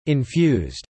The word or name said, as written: infused